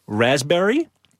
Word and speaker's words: raspberry: K-N-E-E-L, Neil. – Raspberry answer. – Silent right. letters. – Yes, silent letters. Right. Raspberry is? – The silent S. – P. – Or P. Sorry.